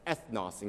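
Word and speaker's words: ethnos (ἔθνος): See, the Greek word for Gentiles is where we get our word ethnicity, because it's pronounced as ethnos in Greek.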